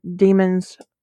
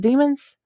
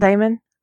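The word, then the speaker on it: daemon: You gotta pick out your demons, whatever you call them. Is it demons? – It's demon. – The A is silent. Now, in my head, I've always said it Damon.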